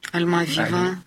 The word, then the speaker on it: Альмавива: я уж боюсь неправильное ударение поставить, Альмавия. – Альмавива. – Альмавива.